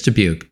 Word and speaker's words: Dubuque: Yeah, I think it is Dubuque.